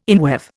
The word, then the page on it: inweave: Indian